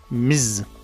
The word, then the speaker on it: Ms.: Okay, but how is this pronounced? Well, it's pronounced Ms. Ms. So when we don't, yeah, it sounds like a bee, I know, trying to collect its honey or whatever it does, but it's not.